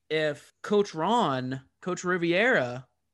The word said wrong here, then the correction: Rivera: or Rivera, sorry, I always mispronounce it.